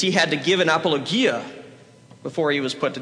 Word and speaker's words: apologia: Not apologia, apologia. That's just a pet peeve of mine. There are no hard G or G's in Greek.